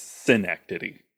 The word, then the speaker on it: Synecdoche: Synecdoche. – Synecdoche. I always say Synecdoche. Synecdoche. Yeah. – But it's supposed to sound like Schenectady. – Schenectady. – But it's Synecdoche.